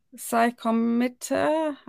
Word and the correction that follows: psychomotor: Psychomotor So yeah, I see.